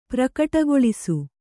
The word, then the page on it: ಪ್ರಕಟಗೊಳಿಸು: kannada